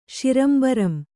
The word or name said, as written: ಶಿರಂಬರಂ